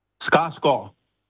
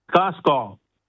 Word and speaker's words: Skarsgård: Alors, comment est-ce qu'on prononce Skarsgård? G-A-R-D. – Skarsgård. – Gore? Vous dites oui. – Skarsgård? – Oui. – Encore une fois. Skar? – Comment vous appelez le nom? – S-K-A-R-S-G-A-R-D. – Skarsgård.